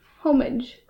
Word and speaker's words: homage: Homage? Homage. How do you think it's correctly pronounced? – I'm going to say homage. – Homage. – With a bit of an A there. Homage.